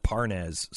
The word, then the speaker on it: Parnas: You know, what's this Lev Parnas? Is that how you say his name? – Yeah.